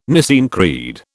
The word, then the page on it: Nicene Creed: British English